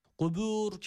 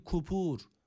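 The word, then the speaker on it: kubur (قبور): Kubur kelimesindeki kafa öncelikle dikkat edelim. Zaten şöyle bir okuyuş var. Fil kubur, kubur diye, kubur diye okunuyor. Yani kaftan bahsediyoruz öncelikle. Kaf harfi kef olarak okunabiliyor. Dikkat edelim. Burada kef harfi yok. Kaf harfi var.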